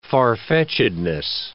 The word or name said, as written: farfetchedness